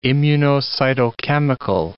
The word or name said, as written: immunocytochemical